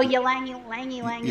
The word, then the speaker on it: ylang-ylang: You said Y-L-A-N-G. How do you say that? – Oh, Ylang-ylang is one of them, but that's not the one I was thinking of.